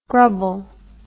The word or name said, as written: grubble